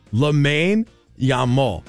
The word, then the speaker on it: Lamine Yamal: Lamine Yamal.